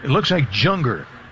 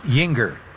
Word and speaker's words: Juenger: Yinger spells it J, looks like Junger, J-U-E-N-G-E-R, but it's pronounced Yinger.